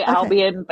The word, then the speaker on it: Albion: Well, speaking of that, you do have an upcoming collection, Albion. Uh-huh. If I said that right, I'm not sure. – I think there's multiple ways to say it. I say Albion, but.